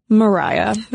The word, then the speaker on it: Maria: Yes, it is spelled to look like Maria, M-A-R-I-A, but it is pronounced Mariah.